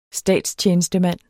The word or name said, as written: STATSTJENESTEMAND